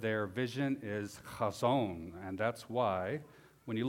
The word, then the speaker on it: Chazon: Chazon. That's a fun word to say. It's like the chet, you pronounce an H sound, at the same time you're clearing the back of your throat. Chazon.